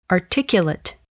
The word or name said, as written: articulate